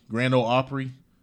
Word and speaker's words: Grand Ole Opry: Now, the number one was some all-access Facebook platform for the Grand Opry. Grand Ole Opry.